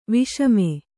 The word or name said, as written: ವಿಷಮೆ